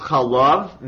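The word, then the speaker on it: chalav (חָלָב): So this Hebrew word can be pronounced chalav, milk, or chalev, fat.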